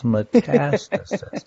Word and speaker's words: metastasis: Metastasis. Accent on the second syllable.